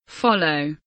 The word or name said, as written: follow